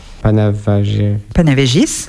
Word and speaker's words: Panevėžys: Panevėžys, merci. – Ah, merci pour la prononciation de ville, parce que c'est vraiment une prononciation lituanienne.